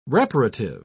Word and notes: reparative: British English

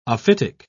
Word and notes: ophitic: British English

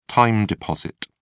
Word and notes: time deposit: British English

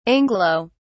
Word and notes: anglo-: British English

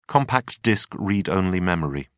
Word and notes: compact disc read-only memory: British English

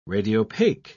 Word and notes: radiopaque: British English

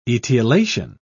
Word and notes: etiolation: British English

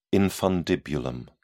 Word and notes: infundibulum: British English

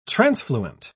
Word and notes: transfluent: British English

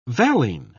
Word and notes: valine: British English

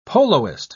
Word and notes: poloist: British English

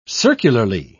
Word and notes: circularly: British English

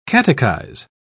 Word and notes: catechise: British English

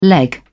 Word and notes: leg: British English

